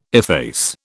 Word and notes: efface: British English

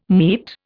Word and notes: meat: Indian

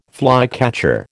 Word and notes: flycatcher: American English

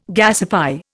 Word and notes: gasify: Indian

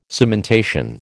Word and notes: cementation: American English

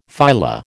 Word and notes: phyla: American English

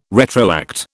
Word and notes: retroact: British English

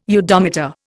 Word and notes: udometer: Indian

